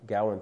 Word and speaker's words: Gawain: How do you pronounce his name? – Gawain. – Thank you. That's right.